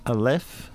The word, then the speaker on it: Aleph: Did I say that right? – Yes, actually, you did. Aleph, however you care to say it, yes. – Okay. – That's the Scottish version. – That's the Scottish version, yes.